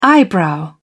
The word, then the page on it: eyebrow: British English